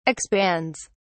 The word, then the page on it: expands: British English